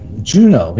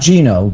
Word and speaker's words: Juneau: He said Utah, Omaha, and Juneau. I heard him say Juneau. – All right, caller. – Was Juneau right? I thought Juneau was spelled something different. Was Juneau right? – Juneau, yeah. Not Gino. Juneau.